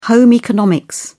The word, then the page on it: home economics: British English